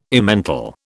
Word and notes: Emmental: British English

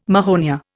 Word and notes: mahonia: Indian

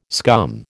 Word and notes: scum: American English

